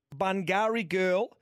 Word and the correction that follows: Burgundy Girl: It's Burgundy Girl, right? – What did I call it?